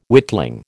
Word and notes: witling: American English

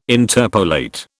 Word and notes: interpolate: British English

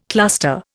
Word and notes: cluster: Indian

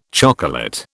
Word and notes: chocolate: British English

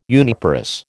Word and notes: uniparous: American English

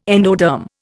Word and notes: endoderm: Indian

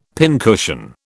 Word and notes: pincushion: British English

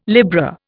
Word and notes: Libra: Indian

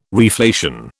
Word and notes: reflation: British English

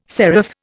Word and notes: seraph: Indian

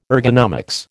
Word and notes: ergonomics: American English

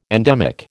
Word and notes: endemic: American English

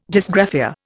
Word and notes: dysgraphia: Indian

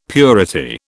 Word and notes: purity: British English

